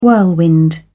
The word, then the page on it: whirlwind: British English